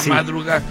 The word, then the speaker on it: Madruga: La madruga. – Con acento en la A, madruga.